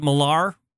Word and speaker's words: Millar: Is it Millar? – Yes, it is.